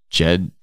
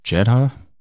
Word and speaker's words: Jedha: escape from Hoth, and secret mission on Jed Jedha.